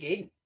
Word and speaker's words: Gaiden: I would say Ninja Gaiden or Gaiden, depending on your pronunciation.